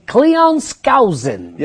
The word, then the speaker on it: Cleon Skousen: Cleon Skousen.